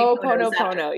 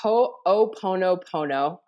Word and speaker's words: Ho'oponopono: It's a Hawaiian prayer of forgiveness and it's Ho'oponopono. I always used to say it wrong. So it's, you know, it's hard to H-O'OPONO, I believe. O-P-O-N-O.